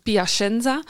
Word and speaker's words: Piacenza: Ehm, en ook daar in die brieven zegt ze van, kijk, eh, ik heb een job gekregen in Piacenza.